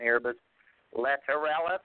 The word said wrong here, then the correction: Lateralus: Lateralus, and the chorus for that is Fibonacci.